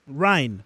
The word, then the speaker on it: Ryan: ¿Ryan? Ryan.